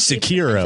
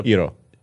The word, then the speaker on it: Sekiro: So not Sekiro. – Just are weird. – Daniel Robeson, the editor-in-chief of IGN Japan, tweeted us and said, it's emphasized the first syllable. That's the... – Sekiro.